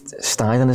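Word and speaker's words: Steidten: I think Alonso would benefit from working with, I think it's Steiden, is it? Tim Steiden? Is that how you say it? Yeah. – That's how you say it. How it's spelled.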